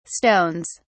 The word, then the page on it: stones: British English